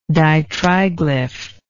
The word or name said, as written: ditriglyph